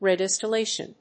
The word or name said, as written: redistillation